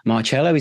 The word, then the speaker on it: Marcello: And on this episode, I'm absolutely delighted to have with me Zach Allen and Harrison Marcello. Is that the right way of pronouncing your surname, Mark? Hello, Marcello. I'm kind of easy on it. Usually the soft C, though.